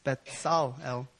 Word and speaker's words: Bezalel: And it's spelled Bezalel, but in the Hebrew, it's Bezalel. Anyways, if you want to turn there, you could turn there.